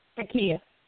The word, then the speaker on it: taqiyya: How do you say it? – Yes, taqiyya.